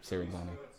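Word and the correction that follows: Cinzano: I keep calling it Serenzano. Senzano. – Senzano. – Senzano. I want to go ahead and publicly apologize for my mispronunciation of the name. It's been Senzano the entire time, not Serenzano.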